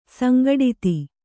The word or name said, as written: ಸಂಗಡಿತ